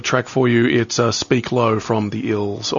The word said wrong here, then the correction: Isles: or the Isles, rather. Is that how you pronounce that? The Isles, Speaking Low.